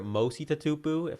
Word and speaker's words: Mosiula Tatupu: All right, your father Mosiula Tatupu.